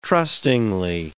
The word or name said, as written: trustingly